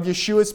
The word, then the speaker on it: Yeshua: So first of all, in the previous chapter, what Paul has been arguing for is the superiority of Yeshua's priesthood over the Levitical priesthood. And if you're watching this later, of course, Yeshua is the Hebrew pronunciation of Jesus.